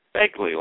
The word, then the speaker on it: Begley: Is it Bigley or Begley? – Begley, like Ed Begley, the actor.